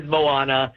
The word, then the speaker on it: Moana: Am I saying that right? I'm out of the kid. I'm out of the kid Disney thing, man. I don't have grandkids yet. My kids are out of the house. I don't watch this stuff now. I have no idea what the hip thing is. – Well, you said it right. It is Moana.